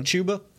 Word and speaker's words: Chuba: Chuba. – Okay. Chuba.